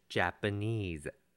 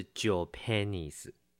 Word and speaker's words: Japanese: 提醒一下发音， Japanese 这个字发音要发好，是 Japanese，不是 Japanese。